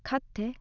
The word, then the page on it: katte: frage